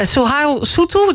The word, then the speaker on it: Souhail Soutou: Uh, Sohail Soutou, dat is zijn naam, zeg ik het goed?